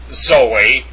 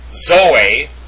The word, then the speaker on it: zoe: It's not straight zoe. It's with kind of a DZ sound, because that's how you pronounce zeta, with a DZ. It's not zoe. It's zoe. Really. Zoe. Not zoe. Zoe. That's how you correctly pronounce it. With a DZ sound for the zeta.